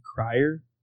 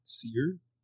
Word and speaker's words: Cyr: And Randall C-Y-R. How'd you pronounce that? – Cryer? – Kai? – Would you say C-Y-R? – Yeah. – Seer? – Oh, yeah. – Cryer? – Randall Seer.